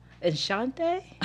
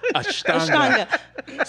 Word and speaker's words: Ashtanga: Enshante? – Ashtanga? – Ashtanga? – Ashtanga.